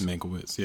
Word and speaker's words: Mankiewicz: and the screenplay by Tom Mankiewicz. – Mankiewicz, yeah.